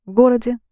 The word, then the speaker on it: городе: Actually, if this «е» is not stressed, it's pronounced as «и» – городе, Новгороде. You can't hear the «е» very well.